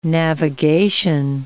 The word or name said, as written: navigation